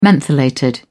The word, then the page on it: mentholated: British English